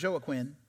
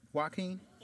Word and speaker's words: Joaquin: I'm saying it right? Not Joaquin, Joaquin. Sometimes in my mind, I say Joaquin, but I know it's Joaquin because I'm smart.